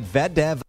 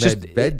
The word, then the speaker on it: Medvedev: Medvedev. – No, it's Medvedev. Just, he's got the wrong emphasis on the wrong syllable.